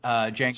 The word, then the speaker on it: Django: Just like the movie, the Django, right, with the D is silent. Django, sure.